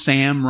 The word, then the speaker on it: Sam: My name, Sam, rhymes with half the English language.